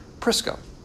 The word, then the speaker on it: Prisca: So this is the Roman pronunciation of her name, Prisca, and Aquila.